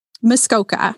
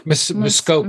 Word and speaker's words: Muskoka: Muskoka. – Muskoka.